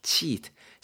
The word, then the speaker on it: cheat: cheat, C-H-E-A-T가 되고요. 발음 여러분 제가 지금 해서 잘 아시겠지만 cheat 이렇게 짧게 하는 게 아니고 cheat 이렇게 길게 발음을 주어야 됩니다.